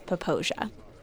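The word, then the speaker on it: Popo Agie: Popowagon River? – What he meant to say was Poposia.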